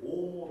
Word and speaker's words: homo: その次の HOMO、これは H を発音しません。ラテン語ですので、ですから法ではなくて OMO と発音されます。